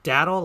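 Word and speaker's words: datil: Pronounced daddle like paddle.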